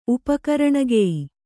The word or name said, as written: ಉಪಕರಣಗೆಯಿ